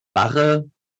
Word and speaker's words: Barre: Barre-Training schreibt man B-A-R-R-E, Barre gesprochen, richtig ausgesprochen, Barre-Training.